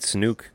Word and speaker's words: Snoke: Snoke, the Supreme Leader Snoke.